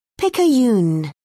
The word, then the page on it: picayune: British English